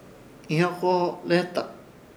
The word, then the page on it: Ihyokoholeheta: Ishir; Chamacoco